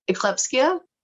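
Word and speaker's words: ekplexia: Eclipseia. – I hate you. I, hate you. It sounds like you said it perfectly.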